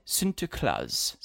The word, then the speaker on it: Sinterklaas: Sinterklaas.